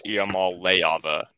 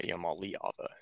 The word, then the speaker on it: Iamaleava: It's Iamaleava, not Iamaleava, And I was saying Leava.